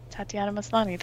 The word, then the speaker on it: Tatiana Maslany: Tatiana Maslany, that's her name.